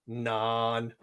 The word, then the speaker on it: naan: As in places like Pakistan, they pronounce it as nan. So it makes sense to spell it as it's pronounced, I guess.